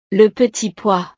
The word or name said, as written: le petit pois